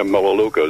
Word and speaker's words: Melaleuca: How you say Melaleuca? Is that M-E-L-U-K-A or what? – M-E-L-A-L-E-U-C-A. – Okay. Alrighty. Because the way it pronounces, it doesn't sound like the way it's spelled.